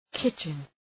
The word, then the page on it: kitchen: ‘kıtʃən